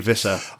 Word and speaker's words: Wissa: that it is pronounced as a W, and it's a V for Valtamada. So yeah, we need to try. – So that's Valtamada and Wissa.